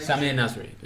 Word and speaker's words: Sami Nasri: Sami Nasri is how, yeah, is, yeah, yeah. how you say.